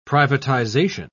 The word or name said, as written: privatisation